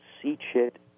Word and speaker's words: CCHIT: Now, some people will wonder what that is, and basically CCHIT is the Certification Commission for Health Information Technology, which likes to be called CCHIT because some people began to mispronounce CCHIT with a soft CH sound.